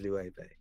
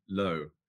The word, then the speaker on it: Loewe: I mean, for starters, I'd just like to congratulate you on your pronunciation. Because before I joined Loewe, I think I was calling it Lo. And it's actually incredible how many different ways you can pronounce these five letters.